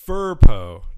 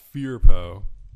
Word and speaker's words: Furpo: So 6 days ago, well, 7 days ago, the comment from MP commented, Furpo, not Fearpo. And that's just, I guess, in reference to how I pronounced Pampiro Fearpo. – Oh, okay. – Like I said Fearpo instead of Furpo.